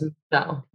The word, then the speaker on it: Mishel: And she phonetically wrote out my name in the way that she thought it would sound like Michelle. – Good. – As opposed to in Spanish. Also, Michelle with the M-I-C-H is Michelle, and she hated that name. So she was like, no, Yes. – We're spelling it M-I-S-H-E-L. And it was Michelle.